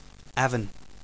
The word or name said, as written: Avon, Victoria